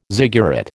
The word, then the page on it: ziggurat: American English